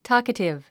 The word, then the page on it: talkative: tɔ́ːkətiv; トーカティブ